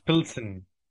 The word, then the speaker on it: Pilsen: And then it's Pilsen at home, which I still don't know if I've been pronouncing that right or wrong. And then them away. – It's Pilsen. Yeah.